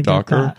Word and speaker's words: Dacre: How do you pronounce that? – That's his Dacre. Dacre.